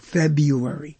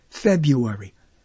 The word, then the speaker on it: February: And someone recently said, you know, if you're going to get on in February, this is how they say it in New York, February. There's no R. February.